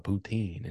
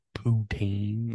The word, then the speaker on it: poutine: They were like, when you go to Canada, you got to try out poutine. And she's like, poutine. Like she like emphasis on the P.